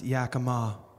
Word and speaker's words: Yakima: I grew up in Yakima, but it's pronounced Yakima, but I never knew.